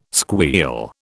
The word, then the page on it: squeal: British English